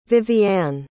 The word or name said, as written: vivianne